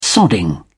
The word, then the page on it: sodding: British English